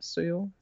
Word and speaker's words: Seoul: So basically, the first one, the Korean film, located in Seoul. Is that how you say it? – Seoul.